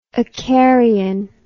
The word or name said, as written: acarian